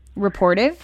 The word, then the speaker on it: Rapportive: Rapportive.